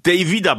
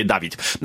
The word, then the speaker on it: David: Mówię Davida celowo, ponieważ część osób po zakontraktowaniu tego zawodnika mówiła Davida, część Davida. Ja też niedokładnie wiedziałem, jak się poruszać, której wymowy tego imienia używać. Wczoraj Brembley powiedział mi, że David to jest jego imię i prosi o używanie takiego imienia, choć no nikogo nie zabije, jak ktoś powie Dawid.